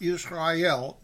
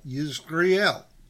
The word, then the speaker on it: Yizreel: The Philistim gathered all their armies to Aphek, and Israel encamped by a spring which is in Israel. Israel, okay? Different, not Israel, Israel. It's spelled differently, Y-I-Z-R-E-E-L.